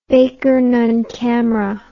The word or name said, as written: baker nunn camera